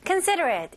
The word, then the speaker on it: considerate: Considerate, 일단 간단하게 사력 깊은 이라는 뜻을 가지고 있고요. 발음 연습해 볼게요. 자, 여기서 음절 하나씩 하나씩 considerate. 마지막에 여기 ate이지만 그냥 at 이런 식으로 발음하시면 돼요.